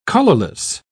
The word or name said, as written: colorless